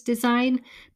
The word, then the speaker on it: design: Why do they like the word design? Because it has a silent G in it.